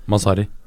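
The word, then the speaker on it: Mazzarri: Med, med tidligere nav på intertreneren Massarini, er det riktig uttalelse? Masari. Masari. Um,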